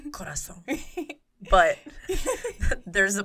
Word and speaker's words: Corazón: how do you say it? – Corazón.